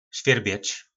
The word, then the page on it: svjyrbec: po nasimu